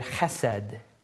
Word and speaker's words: chesed: The word kindness here is a Hebrew word, chesed. Chesed is how it's pronounced.